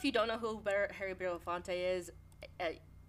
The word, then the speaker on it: Belafonte: Yeah, and we also lost another legend recently, Harry Belafonte.